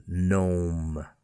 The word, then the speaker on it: gnome: Gnome, here the G is at the front of the word, G-N-O-M-E, but no stress or emphasis on the G, gnome. Align, A-L-I-G-N, align, no stress again on the G. And finally, campaign, C-A-M-P-A-I-G-N.